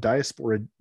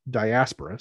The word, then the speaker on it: diaspora: that said the dwarves in the hobbit are were were patterned after tolkien's interpretations of diaspora diaspora sorry i mispronounced that jewish communities in europe mm-hmm.